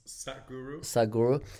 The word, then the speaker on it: Sadhguru: His name is, I think, I hope I pronounce it right, Sadhguru, S-A-D-H. – Sadhguru.